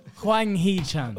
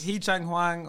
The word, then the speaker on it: Hwang Hee-chan: You need to get it. – Hwang Hee-chan. – Wait, what did I say? – You said something else. – Hee-chang Hwang or Hwan Hee-chang.